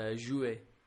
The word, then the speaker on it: jouet: And a toy is just jouet.